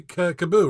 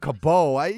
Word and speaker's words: Cabot: It's not Cabot. Right. I said Cabot. Usually the T is silent. Like who has like a T that you pronounce? Like usually it's a silent T.